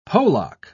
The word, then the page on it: polacca: British English